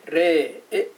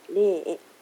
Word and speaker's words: レーエ: そうするとこれ後ろから 2番目の母音なんで、後ろから2 番目のエにあのアクセントがあってレーエっていう風になりますね。 – はい、レーエ。